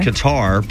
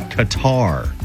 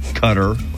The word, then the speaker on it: Qatar: People in Qatar pronounce the name of their country Qatar, not Qatar. Gutter, cutter.